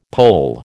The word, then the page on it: Pole: American English